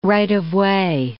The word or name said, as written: right-of-way